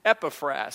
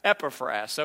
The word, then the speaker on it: Epaphras: Think of sassafras, Epaphras, okay? Scholars don't even seem to agree on how it is to be pronounced.